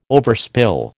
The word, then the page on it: overspill: American English